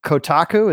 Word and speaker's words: Kotaku: But like, the Kotaku, is that the name of the website?